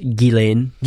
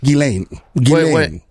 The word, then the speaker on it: Ghislaine: It's pronounced Ghislaine. – Ghislaine. – What do you? – The S is silent.